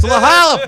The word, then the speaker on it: La Jolla: It's La Jolla, buddy.